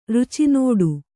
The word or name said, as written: ರುಚಿನೋಡು